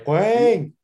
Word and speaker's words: Hwang: Wolves' new signing, Korean Hwang Hee Chan. I hope I pronounced that right. – Hwang.